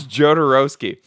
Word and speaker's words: Jodorowsky: It's Jodorowsky.